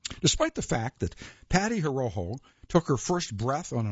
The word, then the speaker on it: Horoho: Patricia Horoho, that's H-O-R-O-H-O. I'm going to pronounce it Horoho.